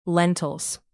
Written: lentils

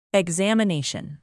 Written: examination

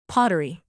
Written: pottery

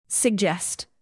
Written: suggest

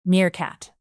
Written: meerkat

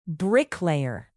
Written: bricklayer

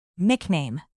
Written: nickname

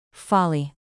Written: folly

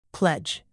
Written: pledge

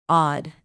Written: odd

